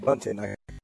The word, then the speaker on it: Bunting: They got one by McCurry last week and got Bunting. – You said it right. – Bunting. – Bunting. – It's like baseball. – Bunting. – Like he's bunting the ball.